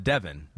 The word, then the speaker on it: Devon: Pete informs me it is pronounced Devon.